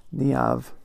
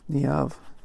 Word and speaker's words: Niamh: I'm going to get her name right. Niamh. Sorry. Niamh.